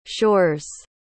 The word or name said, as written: shores